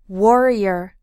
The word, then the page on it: warrior: American English